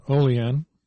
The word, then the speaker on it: Oliand: I'm playing Oleand. O-L-I-A-N-D. The D is silent.